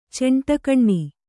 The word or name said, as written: ಚೆಂಟಕಣ್ಣಿ